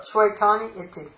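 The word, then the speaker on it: śvetāni: That shvetan? – Huh? – No. I don't know what. – Shvetani iti. – Ah, shvetani. Okay, that makes sense. Yes, because it's a long e.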